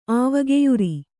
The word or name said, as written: ಆವಗೆಯುರಿ